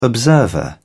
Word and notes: observer: British English

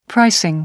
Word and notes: pricing: American English

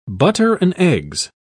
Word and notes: butter-and-eggs: British English

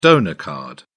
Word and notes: donor card: British English

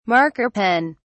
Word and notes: marker pen: British English

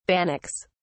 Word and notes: bannocks: British English

